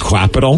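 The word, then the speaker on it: Qapital: It's called Capital, but it's spelled with a Q. – But what about this? – Quapital.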